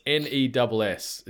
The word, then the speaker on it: NES: And the answer, in quotes, NES, with almost like an emphasis on the S. So it's NESS is how you pronounce it, because you get some people...